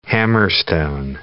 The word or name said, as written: hammerstone